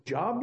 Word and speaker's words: Job: How many of you like the book of Job, you mean? Nobody? Nobody? Well, it's pronounced Job, but it's spelled Job.